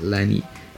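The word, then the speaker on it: LANY: Pero según mi rewind the Deezer, mi Deezer Year, decía que una de las bandas que más escuché es Lani. Es, una banda eh, de Estados Unidos, se escribe L-A-N-Y y esa, esa banda la conocí porque en Apple Music precisamente hace como, que serán?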